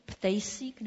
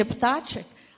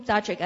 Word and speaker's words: Ptáček: A já jsem za ním předtím šla a říkala jsem, prosím vás, když se píšete Ptacek, jak má s vám vyslovit? Ptejsík nebo ta? Tak a on říkal, vyslovte mě obyčejně Ptáček. A já jsem říkala, no to nic tomu nenapovídá. A on říkal, já se vyslovuji Ptáček. A já jsem říkala, není někdo jako z rodičů nebo prarodičů z Česka?